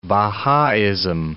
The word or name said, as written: Bahaism